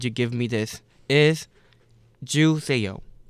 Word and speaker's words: 주세요: It's not too bad, but there's like really easy way to pronounce it. – Yeah. – You know what juice is? – Yes. – And then ayo? – Juiceo. – Juiceo. – That's what it sounds like? – Yep.